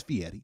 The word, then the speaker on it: Fieri: So Guy Fieri? – Fieri. – Fieri? – Fieri. – Fieri. – Fieri. – If you want to say it correctly, it's Fieri.